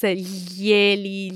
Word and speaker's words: glieli: Bravissimo, è un po' difficile sì. da pronunciare, so I would suggest our listeners practice glieli, gliela, glieli, gliele aloud so that they can familiarize with this tricky sound.